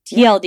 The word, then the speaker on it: TLDR: We're going to talk about copy and making sure things are not TDLR. – Mm-hmm. Which means? – Too long. No, TLDR. Yeah. I said it wrong.